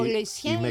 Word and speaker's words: Lucien Laurent: Ο Λουισιέν Λουάν. Μεξικάνοι. – Μεξικάνοι. – Το φάγανε. – Ναι, αφού είπα Γαλλία-Μεξικό. – Ναι. – Θα το πούμε προφορά ξανά. Ο Λουισιέν Λουάν.